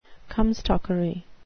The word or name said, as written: comstockery